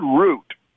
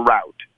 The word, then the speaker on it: root: The word root can be pronounced either root or route in the U.S.